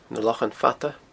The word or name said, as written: Na Lochan Fada